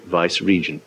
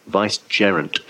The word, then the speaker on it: vicegerent: He was vicegerent in spirituals, a title no one has ever held in England before, so everyone always mispronounces it vice-regent. No, vicegerent.